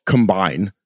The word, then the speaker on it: combine: To avoid confusion between these words, we basically always put the stress on the first syllable to show a word is a noun and on the second syllable to show it's a verb, so combine or combine.